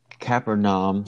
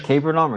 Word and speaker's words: Capernaum: And when he returned to Capernaum, did I say it right this time? – You said it right this time. – Capernaum or Capernaum? – Either or.